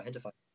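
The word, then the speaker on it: Gentefied: It's like, it's spelled with a G, gentified, but it's like pronounced hentified.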